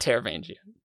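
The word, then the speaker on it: Taravangian: It's not Teravangian. Not going to say a hard G. There were some comments about that. No. I pronounce it this way forever.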